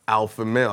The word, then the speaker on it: Alphamel: You got my man Alphamel. I said that right? – Yeah, you said it right.